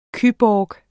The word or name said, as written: cyborg